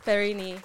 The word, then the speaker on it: Perenyi: Brigitte Perenyi.